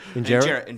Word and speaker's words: injera: Injera.